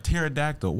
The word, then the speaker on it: pterodactyl: Yeah, it'd be rough because spelling, man, because it's words, like you said, it's letters in there, like pterodactyl. Why the fuck is it a P? – Right there, just silently.